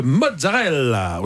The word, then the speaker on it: mozzarella: Loana Belmondo, on parle de mozzarella aujourd'hui. Déjà, est-ce qu'on prononce bien ? C'est mozzarella ? – Mozzarella. – Mozzarella. Mozzarella. – Vous le dites très très bien.